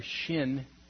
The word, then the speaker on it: Shin: It is the letter Shin, pronounced S-H, and it's the first letter in the word Shema.